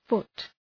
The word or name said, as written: foot